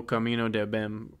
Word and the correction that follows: O Caminho do Bem: Jesus Christ, I butchered the fuck out of that. Whatever that yeah, one is. Um, um, it's Que Beleza and O Caminho do Bem.